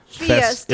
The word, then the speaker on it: Fiesta: Sadly, it's Balloon Festa, is I think how you pronounce it. – Fiesta.